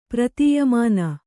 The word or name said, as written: ಪ್ರತೀಯಮಾನ